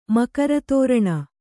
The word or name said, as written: ಮಕರತೋರಣ